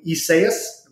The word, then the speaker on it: Isaias: I mean, Isaias, if I pronounced that correctly. – That's right.